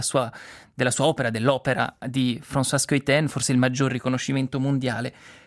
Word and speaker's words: Schuiten: diciamolo giusto, François Schuiten, appunto gli, da italofoni leggeremmo Schuiten probabilmente, visto che si scrive con Schui, ma l'origine belga, ecco, ci impone questa pronuncia.